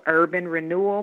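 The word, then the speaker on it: Herban Renewal: It's called Urban Renewal, but it's spelled H-E-R-B-A-N.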